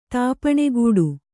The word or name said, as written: ತಾಪಣೆಗೂಡು